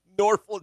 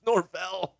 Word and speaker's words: Norvell: He's Norval. It's Norvell.